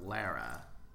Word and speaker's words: Laura: It's confusing because one of the characters is Lara, it's spelled out Laura. Like Tim Roth looks at an engraved watch that she gave him, "Forever Yours," L-A-U-R-A, but they only ever call her Lara.